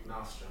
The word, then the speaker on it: maelstrom: M-A-E-L. I'm gonna edit this. Mal? How do you pronounce it? – Maelstrom. – Maelstrom, M-A-E-L-S-T-R-O-M, bitch.